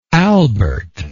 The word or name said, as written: albert